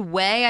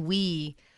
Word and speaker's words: whey: It's pronounced whey. I thought it was wee.